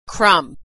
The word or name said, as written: crumb